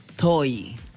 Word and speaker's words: Tohi: T-O-H-I. ¿Y? TOY. – TOY. – Se pronuncia TOY.